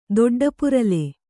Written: ದೊಡ್ಡಪುರಲೆ

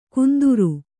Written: ಕುಂದುರು

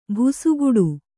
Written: ಭುಸುಗುಡು

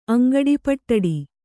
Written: ಅಂಗಡಿಪಟ್ಟಡಿ

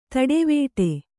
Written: ತಟವಟ

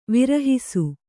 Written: ವಿರಹಿಸು

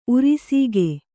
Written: ಉರಿಸೀಗೆ

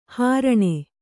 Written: ಹಾರಣಿ

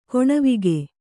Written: ಕಣೆವೋಗು

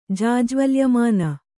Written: ಜಾಜ್ವಲ್ಯಮಾನ

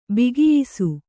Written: ಬಗೆಯಿಸು

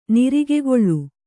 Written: ನಿರಿಗೆಗೊಳ್ಳು

